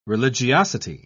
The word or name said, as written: religiosity